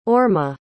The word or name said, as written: orma